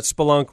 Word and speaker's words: spelunk: Just remember that spelunk rhymes with kerplunk.